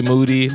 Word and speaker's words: Moody: As far as non-skater, I would go with none other than G Monetti, G Moody, last name rhymes with Duty, from Michael Rappaport's podcast.